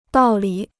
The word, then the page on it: 道理: dào li